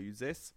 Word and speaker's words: Uzès: Das ist bei Uzès. Und ja, das wird wirklich so ausgesprochen.